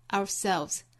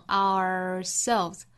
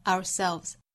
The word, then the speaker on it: ourselves: ourselves help 里面包含舌侧音 help 最后这个词读成 ourselves 当然我们也可以读成 ourselves 好我们来用非常慢的速度用美音来读一下这句话 It's only by helping others that we can truly help ourselves 注意最后这个词重读在后面这个音节 ourselves 好发音部分我们就讲到这儿了我们来看这句话的意思这句话里面其实包含了一个强调句式，就是 it is 什么什么 that 什么什么，要强调的部分呢放到 it is 和 that 之间就可以了，可以强调主语、宾语、地点或者是时间。